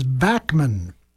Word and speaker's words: Bachman: The gentleman's name is Tal, T-A-L, Tal Backman. I was going to say it wrong because that's the way he pronounces it, is Backman. His father is Randy Bachman. At least that's the way he says it, a Bachman Turner Overdrive. Don't ask me why, but if you ask him, you'll say his name is Tal Backman.